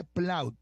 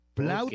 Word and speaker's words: Plaud: ¿Se escribe P-L-O-U-T? – Se escribe Plaut, P-L-A-U-D, Note, de nota. Plaut, nota, P-L-A-U-D, Note, así se llama.